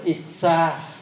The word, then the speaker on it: Issa: Sân phần tâm sở có 4 tâm sở, đó là đô xả là sân, à, Issa, cái này đọc là Issa,